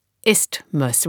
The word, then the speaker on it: isthmus: I also had trouble with isthmus. So in the end, I just dropped the H in the middle and pronounced it isthmus, which I hope is right.